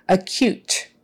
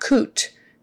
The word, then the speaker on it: acute: Just remember that cute part, how you say it when someone is cute, it's with a K-Y-U sound, like cute, not coot, cute, acute. And the stress is on the second half, acute, meaning very serious, right?